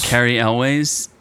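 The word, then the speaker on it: Cary Elwes: I like Carrie Ewes or whatever. Ewes. – Carrie Elwes. How do you not know who that is? – No, he's awesome. Okay. I just don't know how to pronounce his name.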